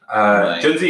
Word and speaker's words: Junzi: Junzi is what most people pronounce it to be. The Chinese way of pronouncing it is Junzi. – Junzi. – Junzi. So kind of like June, like month, and like pizza.